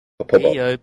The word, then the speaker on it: Poppopo: P-O-P-P-O-P-O. Long O. Popobo.